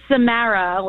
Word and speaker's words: Samara: It's Samara with like a Long Island accent.